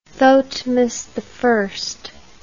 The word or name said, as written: thotmes I